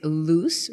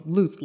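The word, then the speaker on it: Luz: Okay, so my name is Luce Rios. It's L-U-Z. It's like Lucy without the E. I just tell people it's Luce like goose, but Luce.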